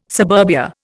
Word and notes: suburbia: Indian

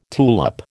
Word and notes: tulip: American English

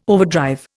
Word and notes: overdrive: Indian

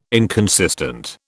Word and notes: inconsistent: British English

